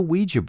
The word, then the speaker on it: Ouija: So where did the Ouija board get its name? And I pronounced it Ouija board because that's how it's actually pronounced. – Yeah. – Not Ouija, it's Ouija.